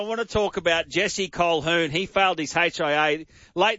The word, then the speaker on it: Colquhoun: Big man, does it annoy you that Jesse Colquhoun you pronounce it Colquhoun, but it's spelt Colquhoun.